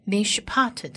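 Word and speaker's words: mishpat (משפט): 再来往下看下一个字是 mishpat 是公义的意思。公义这个字它有四个字母要学，第一个字母是希伯来文字母的 man 的话，在这里发音是发 m 的音，加上它下面的一点是短母音 e，所以是 m 音 mishpat 的 me。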